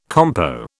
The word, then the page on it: compo: British English